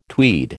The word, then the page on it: tweed: American English